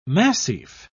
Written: massif

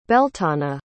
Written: beltana

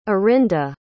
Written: orinda

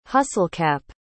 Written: hustlecap

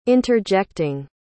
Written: interjecting

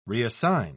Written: reassign

